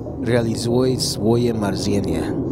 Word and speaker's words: Realizuj swoje marzenia: How do you pronounce that again? – Realizuj swoje marzenia.